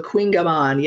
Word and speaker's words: kouign-amann: One I might even mispronounce, Kunyaman. – Oh, you got it. Very good. Yeah. It's the phonetic is K. It's like K wing. So Kunyaman. Yeah, you got it.